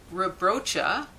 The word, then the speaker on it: Rebraca: Rebrocha, and it's spelled R-E-B-R-A-C-A, is a 6'9" center who doesn't just plant himself in the low post and stay there.